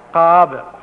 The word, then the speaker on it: Kaaba: Kaaba, right? There's a Ayn, you have to swallow the your throat to get that Ayn pronounced properly.